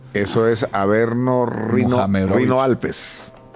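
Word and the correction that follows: Rhône-Alpes: Rone Alpes. – O Rone Alpes, sí.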